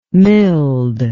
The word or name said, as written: milled